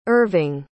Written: erving

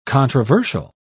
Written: controversial

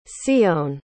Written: tsiyone

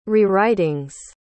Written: rewritings

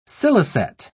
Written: scilicet

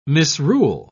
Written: misrule

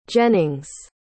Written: jennings

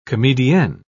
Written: comedienne

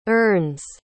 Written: urns

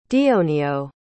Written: dionio

